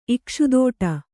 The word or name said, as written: ಇಕ್ಷುದೋಟ